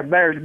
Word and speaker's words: Bairs: It's called Bears, right? – Yeah, Bears. B-A-I-R-S. Bears.